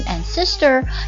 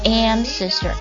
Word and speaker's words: ancestor: 注意一下这个祖先单词的读音呢，不要读成 ancestor，要读成 ancestor，重音在第一个音节上 ancestor。